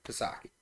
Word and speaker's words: Psaki: Yeah, Psaki.